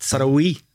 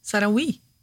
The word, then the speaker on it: Saraoui: Ja, det och Saraoui som jag har sagt. – Saraoui. – Ja, men det är liksom Oui ska vara som en VI. Saraoui.